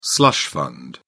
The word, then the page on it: slush fund: British English